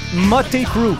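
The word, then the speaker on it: Mötley Crüe: C'est Motley Crue. – Ben oui! Ben oui! Que l'on prononce Motley Crue, effectivement.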